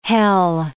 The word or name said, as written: Hel